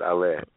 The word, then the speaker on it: Ale: It's Ale. – Okay, it's the French sound.